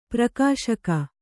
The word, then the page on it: ಪ್ರಕಾಶಕ: Kannada